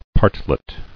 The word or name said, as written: Partlet